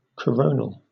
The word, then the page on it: coronal: British English